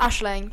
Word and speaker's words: Aisling: It's pronounced Aisling. It's a name in Irish.